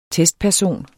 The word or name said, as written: TESTPERSON